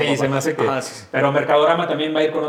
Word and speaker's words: Mercadorama: está este Mercadorama. Y lo dije bien porque siempre les digo modelorama. – Siempre, pero Mercadorama.